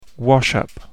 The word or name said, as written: wash up